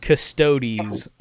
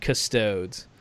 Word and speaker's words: Custodes: He says, a friendly reminder, it's pronounced custodies, not custodes.